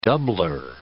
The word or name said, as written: doubler